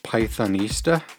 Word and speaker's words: Pythonista: Pythonista. Pythonista, and that's spelled P-Y-T-H-O-N-I-S-T-A, Pythonista.